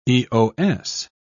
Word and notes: Eos: British English

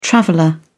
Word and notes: traveller: British English

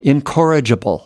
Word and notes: incorrigible: American English

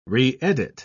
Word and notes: reedit: British English

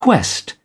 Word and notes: quest: British English

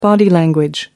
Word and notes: body language: American English

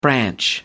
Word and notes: branch: American English

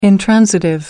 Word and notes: intransitive: American English

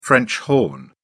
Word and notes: french horn: British English

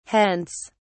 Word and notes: hence!: British English

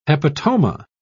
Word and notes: hepatoma: British English

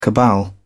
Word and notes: cabal: British English

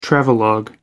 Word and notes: travelogue: American English

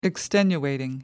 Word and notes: extenuating: British English

